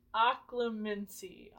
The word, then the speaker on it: Occlumency: Occlumency. It's made up. So that's how you say it.